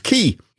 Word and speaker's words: Qiui: Key is spelled Q-I-U-I, but pronounced Key.